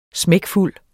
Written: smækfuld

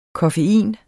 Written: koffein

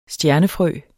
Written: stjernefrø

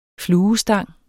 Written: fluestang

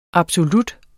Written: absolut

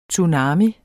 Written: TSUNAMIER

